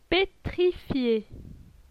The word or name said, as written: pétrifié